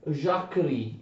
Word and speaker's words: Jacquerie: Attenzione, questa rivolta dei contadini questa avviene nel maggio-giugno 1358, viene chiamata Jacquerie, attenzione, Jacquerie, ma si legge Jacquerie in francese.